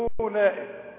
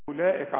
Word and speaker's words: أولئك: تكتب الحرف وما تنطقوش زي المعنى. أولئك، ما تقولش أولئك، قول أبدا أولئك على طول، تلغي الحرف ده، ولازم تمد المد ده.